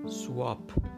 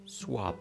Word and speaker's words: swab: آنچه ما میگوییم برخی از ما البته سواپ آنچه آنان میگویند سواب سواب S W A B سواب